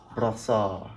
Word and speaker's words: Brossard: Growing up in Brossard, Quebec, as you've just told me that's how you pronounce it. – Brossard.